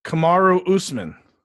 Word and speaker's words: Kamaru Usman: And Kamaru Usman.